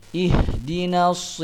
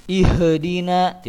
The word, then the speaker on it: ihdina: Ihdinas siratal mustaqim. Yang perlu diperhatikan dalam ayat ini, ihdinas siratal mustaqim. Ih, maka tidak boleh dikolokolahkan, maksudnya dipantulkan. Ihdina, tidak boleh. Tapi dibacanya ihdina, tidak boleh ihdina.